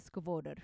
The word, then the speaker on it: Skvader: So Skvader is actually spelled Skvader because it's S-K-V-A-D-E-R, but the pronunciation of the guy is officially Skvader. So yes, that's where I'm at. So if you do read my notes and you see that I've, in your head, been pronouncing it wrong the whole time, it's spelled Skvader, but it's pronounced Skvader.